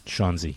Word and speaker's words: Shaunzi: That I asked him also about the way he portrayed his character, Shaunzi, which rhymes with Fonzie.